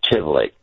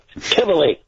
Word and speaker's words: Tivoli: You're probably familiar with the Tivoli Gardens in Denmark, Copenhagen, Denmark. – I've heard of them. – Well, it's the same pronunciation.